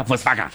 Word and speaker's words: Volkswagen: Volkswagen, que se pronuncia Volkswagen.